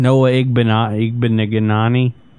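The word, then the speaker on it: Igbinoghene: How do you pronounce that guy's last name? – Noah Igbinoghani. – El Fartiaga gets down on us all the time because we can't pronounce this. Igbinoghani, I think, is how it's pronounced.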